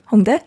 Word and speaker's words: Hongdae: Am I saying that right? – Hongdae? Yeah.